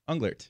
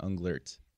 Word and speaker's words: Unglert: Unglert, yeah. – There you go. – A lot of people don't pronounce the T at the end, Unglert.